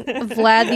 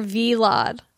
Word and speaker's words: Vlad: Well, that's like calling Vlad the Taylor V-Lad. – I was calling him V-Lad. And someone on Instagram was like, you know, it's Vlad one syllable.